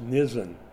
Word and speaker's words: Nisan: It looks like Nissan, the car company, but it's pronounced Nisan.